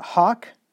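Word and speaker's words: hough: Thou shalt hawk, that's actually the pronunciation of that, I believe, thou shalt hawk their horses and burn their chariots with fire.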